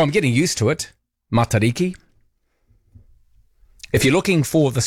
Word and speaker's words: Matariki: Roman, your pronunciation of Riki in Matariki is great. But use mata instead of mata for the mata part. No macron or long vowel in mata.